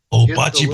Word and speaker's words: bocce: bocce ball? – Yeah, bocce ball.